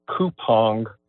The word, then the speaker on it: Coupang: The company's name, I understand, is pronounced Coupang.